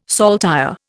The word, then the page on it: saltire: Indian